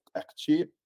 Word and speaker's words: Kekchi: That's exactly how you pronounce it. If you're pronouncing it in English, if you're pronouncing it in the actual language, it's pronounced Kekchi. So a little bit different.